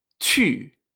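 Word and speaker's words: chu (去): Y esto se dice chu. Chu. Así se pronuncia el verbo ir en chino. Chu. Fíjate que esa u se pronuncia y, aunque no tenga diéresis.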